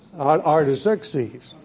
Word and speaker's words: Artaxerxes: Did I say that right? Artaxerxes. She corrects me, and I still can't remember it.